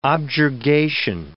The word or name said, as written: objurgation